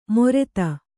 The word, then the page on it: ಮೊರೆತ: kannada